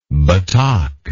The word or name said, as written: Batak